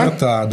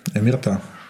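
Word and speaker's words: omertà: Омерта, да. Ударение на последнем слоге – омерта. Не омерта, как иногда можно услышать.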